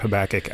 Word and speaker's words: Habakkuk: Did I say it right? – You did. Good job. – Yes. – Of course, we're all assuming that's how it's said, because there is nothing known about Habakkuk outside the prophecy of Habakkuk.